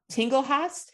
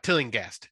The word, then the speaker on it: Tillinghast: So Jeffrey Combs is Dr. Crawford Tinglehast. Is that how you say it? – Tinglehast? Tillinghast.